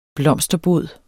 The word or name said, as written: blomsterbod